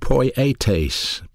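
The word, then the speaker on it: poietes (ποιητής): And it's called poietes, poietes. P-O-I-A-Y-T-A-C-E is the way you pronounce it, poietes. You spell it P-O-I-E-T-E-S, poietes, P-O-I-E-T-E-S.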